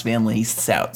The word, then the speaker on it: Van Leeshout: My name is Justin Van Leeshout. That's spelt Van Leeshout, but pronounced Van Leeshout.